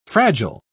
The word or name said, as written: fragile